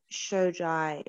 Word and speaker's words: Shojai: On Facebook and Instagram, it's Jasmine Shojai Model. My last name is spelled S-H-O-J-A-I.